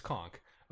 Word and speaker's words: conch: Do the pronunciation click thing. It's conk. Okay.